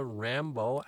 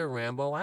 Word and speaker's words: Rambo: And she said, they're called a Rambaud apple. And I said, what? And she said, a Rambaud apple. And I said, spell it. And she said, R-A-M-B-O.